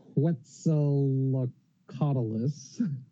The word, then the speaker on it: Quetzalcoatlus: I polymorph myself into a Quetzalcoatlus.